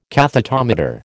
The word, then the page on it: cathetometer: American English